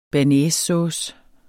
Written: bearnaisesauce

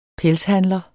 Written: pelshandler

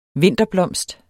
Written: vinterblomst

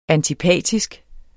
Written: antipatisk

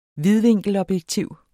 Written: vidvinkelobjektiv

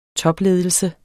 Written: topledelse

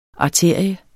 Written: arterie

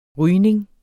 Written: rygning

